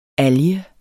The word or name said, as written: alge